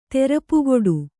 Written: ತೆರಪುಕೊಡು

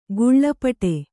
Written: ಗುಳ್ಳಪಟೆ

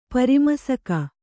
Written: ಪರಿಮಸಕ

